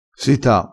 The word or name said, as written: sita